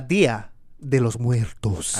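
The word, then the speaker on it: Día de los Muertos: La Dia de los Muertos. – I don't think that was it. – That's exactly it. Perfect accent.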